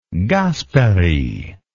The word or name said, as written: Gasperi